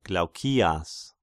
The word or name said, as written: Glaukias